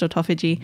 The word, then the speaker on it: autophagy: Autophagy. – Okay. – But when you read it, it's spelt like autophagy. – Okay. – It's pronounced autophagy.